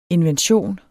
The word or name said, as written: invention